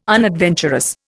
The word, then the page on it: unadventurous: Indian